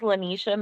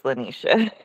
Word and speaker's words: Leonitia: I know, and it's interesting spelling, so people always like mess it up, but yeah, it's Leonitia.